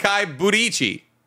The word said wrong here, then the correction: Budokai Tenkaichi: Mm. Buddha Tenkai. – You're closer. – Tenkai Budichi? – You're close. Budichi. Swap them. – Budichi Tenkai. – Budokai. – Budokai Tenichi. – Tenkaichi. – Budokai Tenkaichi. – Yeah, you're like seven.